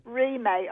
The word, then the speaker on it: Remay: Remay, R-E-M-A-Y.